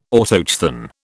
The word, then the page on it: Autochthon: British English